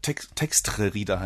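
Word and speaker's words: txtr Reader: Ja, Texter-Reader heißt das. Man muss vielleicht mal kurz buchstabieren. Das wird T-X-T-R, also frei von jedem Vokal geschrieben, für die, die es zu Hause mal kurz aufrufen wollen.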